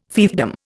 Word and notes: fiefdom: Indian

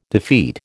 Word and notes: defeat: American English

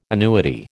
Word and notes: annuity: American English

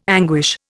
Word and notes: anguish: Indian

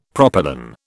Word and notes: propylon: British English

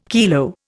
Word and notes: kilo: Indian

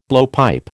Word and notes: blowpipe: American English

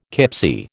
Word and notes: kipsie: American English

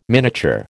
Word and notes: miniature: American English

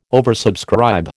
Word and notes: oversubscribe: American English

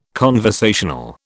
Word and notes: conversational: British English

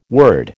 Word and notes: word: American English

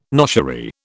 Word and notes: noshery: British English

